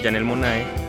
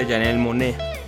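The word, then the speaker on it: Janelle Monáe: Y de hecho también eh, me corrigieron en Tajín, no sé, Janel Monae, yo, yo decía Janel Monae se escribe y en realidad no es que una palabra muy común o un nombre muy común, pero se pronuncia Janel Monet.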